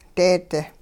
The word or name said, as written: deedi